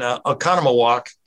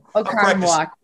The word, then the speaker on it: Oconomowoc: Yeah, I saw one of your brothers had a birthplace, Trenton, who's playing hockey, had a birthplace in Oconomowoc. Did I say that right? – Yeah. – Yeah. – Yep. Oconomowoc. Yep. – Yeah, practice, whatever.